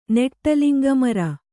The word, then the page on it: ನೆಟ್ಟಲಿಂಗಮರ: Kannada